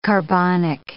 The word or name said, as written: carbonic